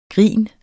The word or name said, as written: grin